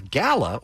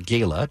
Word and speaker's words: Gala: apple, as Gala, or Gala, depending on how you pronounce it, takes the number one spot.